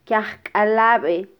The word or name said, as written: kyajkalabe